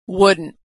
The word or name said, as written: wouldn't